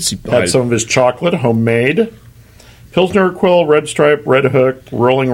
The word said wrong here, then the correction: Pilsner Urquell: Yeah, Pilsner Urquell.